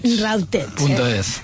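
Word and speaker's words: Rawtech: R-A-W-T-E-C-H. A ver, decirlo vosotros, que me gusta más. – Routech.es.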